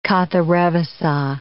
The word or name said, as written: Katharevousa